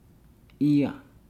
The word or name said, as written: ia